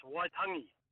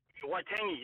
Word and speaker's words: Waitangi: Just just a heads up, brother, just with the pronunciation, brother, it's Waitangi. – Waitangi, that's what I said, didn't I? – Waitangi, you're saying Waitangi.